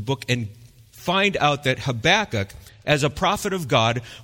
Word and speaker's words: Habakkuk: Now, we're going to be walking through the book of Habakkuk, and I pronounce it Habakkuk. Some people might say Habakkuk or Habakkuk, but I say Habakkuk, just because it's easier for me to go.